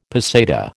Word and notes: peseta: American English

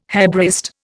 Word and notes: Hebraist: Indian

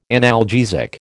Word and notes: analgesic: American English

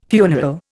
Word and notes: funeral: Indian

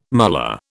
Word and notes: mullah: British English

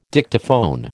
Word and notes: Dictaphone: American English

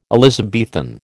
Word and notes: Elizabethan: American English

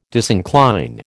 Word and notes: disincline: American English

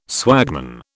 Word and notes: swagman: British English

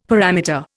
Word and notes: parameter: Indian

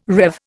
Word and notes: rev: Indian

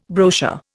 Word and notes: brochure: Indian

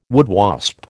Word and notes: woodwasp: American English

